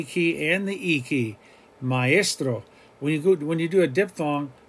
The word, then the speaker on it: maestro: Example, maestro, maestro. That's not a diphthong, even though it might sound like I, maestro. Maestro would be if you pronounce it like a diphthong, maestro. But we're saying maestro, maestro. We're hitting the A and we're hitting the E.